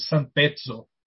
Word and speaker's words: Sampezzo: Saíram quatro edições pela Martins Fontes de um personagem dele, que é um detetive meio noir, chamado Sampeso, né? ou então, mais italianadamente falando, Sampezzo, com, com dois Z. É isso mesmo, Marcão, a pronúncia? Que é em pizza? São é Acertou, parabéns, cara.